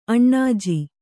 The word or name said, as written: ಅಣ್ಣಾಜಿ